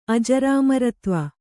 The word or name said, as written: ಅಜರಾಮರತ್ವ